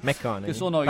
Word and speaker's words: McConaughey: E se la prima stagione è piaciuta a tutti, dal mio punto di vista, lo deve fondamentalmente all'immenso lavoro di Harrison e McNagley, che sono, che io non lo so pronunciare, McConaughey. Però McNagley, prendetelo per buono.